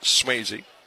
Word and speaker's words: Souzzi: And the pitch inside and low, S-O-U-Z-Z-I, pronounced Swayze.